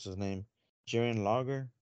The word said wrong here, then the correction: Jushin Liger: Oh, you're talking about Jerry Lynn? – No, he's talking about Jushin Liger.